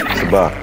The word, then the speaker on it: Sabacc: And also, if you pronounce Sabacc incorrectly around Lando and call it Sabacc, he'll correct you. – He sure will. – So this is Sabacc? – Sabacc. – Sabacc. Got it.